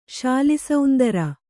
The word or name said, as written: ಶಾಲಿಸೌಂದರ